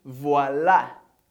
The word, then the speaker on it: voilà: Primeiro, a gente tem voilà.